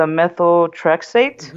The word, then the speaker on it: methotrexate: So they were going to put me on, I always have a hard time pronouncing it, but I believe it's a methotrexate. – Mm-hmm. That's right. Yep.